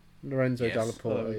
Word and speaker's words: Dalla Porta: Lorenzo Dalla Porta, yes.